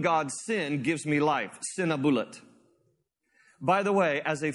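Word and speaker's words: Sîn-uballiṭ: His name in the ancient Akkadian language actually is pronounced Sinabulat.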